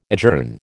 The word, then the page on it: adjourn: American English